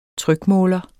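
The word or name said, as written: trykmåler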